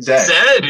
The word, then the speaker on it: zed: Oh, it was Zed, right? Zed. – Zed is correct. – Because isn't Zed like the British version of Z or something? Or is it the... – It's the British pronunciation of Z.